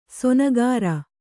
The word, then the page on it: ಸೋನಗಾರ: Kannada